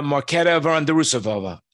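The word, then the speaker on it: Markéta Vondroušová: Is that her name? Mandrusova? – No, Marketa Vondrusova. Yeah, she's got like multiple highlights on her name.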